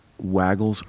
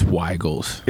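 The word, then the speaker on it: Weigel's: Are you a guy that pronounces it Waggles or Weigels? – It's Weigels. – It is Weigels. – It's officially Weigels.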